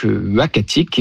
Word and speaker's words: Uhackatic: Alors, le nom, ça sera donc Uhackatic, et ce nom-là, donc il s'écrit U-H-A-C-K-A-T-I-C, nous a été proposé par le conseil des Inuits d'Ekunitchit, qu'on a contacté justement pour avoir leur avis.